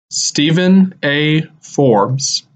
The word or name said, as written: Stephen A. Forbes